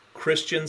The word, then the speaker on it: Christion: So if you just go at Christian Sadler, which is spelled C-H-R-I-S-T-I-O-N, Sadler with one D, S-A-D-L-E-R. So at Christian Sadler, I'm really easy to find.